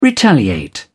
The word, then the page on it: retaliate: British English